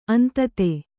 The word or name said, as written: ಅಂಧತೆ